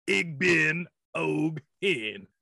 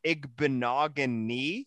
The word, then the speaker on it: Igbenogany: You were basically one syllable away from being right. Noah Igbenogany. – Igbenogany. – Yeah, at least you didn't say Igbenogane like most of our guests.